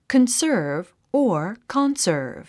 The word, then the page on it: conserve: kənsə́ːrv; カンサーブ